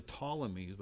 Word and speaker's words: Ptolemies: And I've heard of the Ptolemies, but I really didn't know what that was all about. I didn't know their name was spelled with a P. P is silent at the beginning.